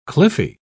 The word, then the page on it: cliffy: British English